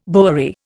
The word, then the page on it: bowery: Indian